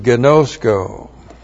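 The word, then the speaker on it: Ginosko (γινώσκω): And the word there, to come to know, is the Greek word ginosko. Looks like that in the Greek. That's G-I-N, long O-S-K, long O. Ginosko.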